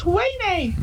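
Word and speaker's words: Ouaisné: And we live next to a place that's spelt O-U-A-I-S-N-E. But it's pronounced Wayne.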